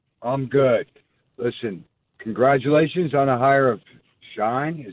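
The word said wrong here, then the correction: Schoen: Is that how you say his name? – Joe Shane. – Shane. – Mm-hmm. – Joe Shane. Okay.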